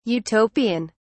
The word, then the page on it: Utopian: British English